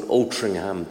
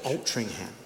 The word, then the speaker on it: Altrincham: It's called Altrincham Baptist Church. – Altrincham.